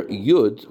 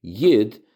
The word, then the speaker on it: Yud: The letter Yud, or according to the Polish pronunciation, Yid, is the beginning of God's name and every Jew's deepest self, the part of God within, their Yud.